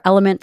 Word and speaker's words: LMNT: I'm super grateful for Element for sponsoring this episode of the Dr. Gabrielle Lyon Show. It is spelled L-M-N-T and pronounced Element.